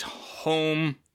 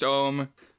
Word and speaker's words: homme: the french word for man which is home or it might be pronounced ohm h-o-m-m-e uh,